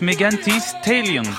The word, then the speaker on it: Megan Thee Stallion: On vient d'écouter Gucci Mane avec Megan Thee Stallion.